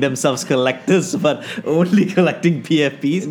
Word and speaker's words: PFP: It shits on the PSPs. – Oh, PFPs. I mean, you need to pronounce it right first before you can fight against it, boy.